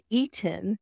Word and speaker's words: Eatin': Garden of Eatin'. Like I'm Eaton. E A T I N.